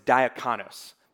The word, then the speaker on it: diakonos: is a Greek word known as diakonos.